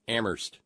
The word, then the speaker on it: Amherst: I'm sorry, Amherst, where the H is silent, and where our pal Terry is.